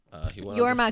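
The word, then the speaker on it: Jorma: Yorma, because it's a Y sound. We've agreed with that. – It's a soft J.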